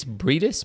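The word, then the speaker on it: Briedis: It's actually probably Maurice. Maurice Bredis? Bredis? It's like Brie Cheese and then Dis.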